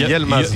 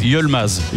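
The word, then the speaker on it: Yılmaz: Est-ce qu'on ne doit pas dire Yilmaz alors, puisque le I se prononce E. Donc exact. On a Y-I, donc c'est Yilmaz. – Yilmaz. – Yilmaz, pas Yel.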